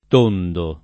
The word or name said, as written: tondo